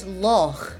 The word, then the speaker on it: loch: In words such as loch, as in Loch Ness, we also have quite a harsh kind of Germanic sound.